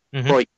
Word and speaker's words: Broik: Cousin of Broik, Broik, how we pronounce it.